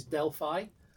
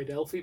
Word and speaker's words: Delphi: It took place in Indiana, in a town called, and I'll pronounce it the way they pronounce it, which is Delphi. I would say Delphi, but that's how the locals call it. That's what it's called, Delphi.